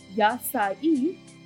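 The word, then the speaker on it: Yasai (野菜): So the numbers 8, 3, and 1, so 8 for August and 2, 1 for the day itself, which can be pronounced as Yasai, means vegetables, so therefore dubbed Vegetable Day.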